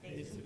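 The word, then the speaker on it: Jesús: Just like, for instance, what is Jesus in Spanish? Jesus. Jesus. You don't pronounce the J. I mean, for instance, it's like when, what is it? It was always funny to me. In our Spanish textbooks in high school, you know how they spelled out laughter in the book? Ja, ja, ja, ja, ja, ja, ja. Because the J is pronounced as an H, so it's ha, ha, ha.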